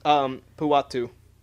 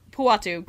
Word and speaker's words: Puatu: as is Griffin Patau. I think I pronounced his name right. – Puatu. – Puatu. Griffin Puatu.